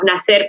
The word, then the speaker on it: Nacer: And when the Lebanese side came to Cuba, they changed the spelling of Nacer from N-A-S-S-E-R to N-A-C-E-R, which is, yeah, a Cuban word.